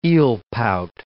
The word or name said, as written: eelpout